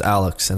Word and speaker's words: Aleks: And so it's like, you know, I'll tell people, like, hey, my name's Alex.